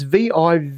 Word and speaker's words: Vivino: The answer is this app, and it's called Vivino. I think that's how you say it. It's Vivino.